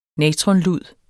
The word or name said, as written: natronlud